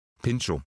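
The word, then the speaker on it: Pintxo: sondern dieses X wird ausgesprochen, deswegen Pincho.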